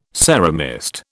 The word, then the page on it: ceramist: British English